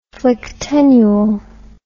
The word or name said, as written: phlyctenule